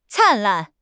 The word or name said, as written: 灿烂